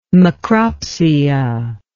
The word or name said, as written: macropsia